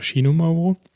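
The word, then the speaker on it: Xinomavro: X, mit X schreibt man das. Xinomafro mit V, das spricht man, wenn ich das richtig kapiert habe, als Xinomauro. Vielleicht auch Xinomauro.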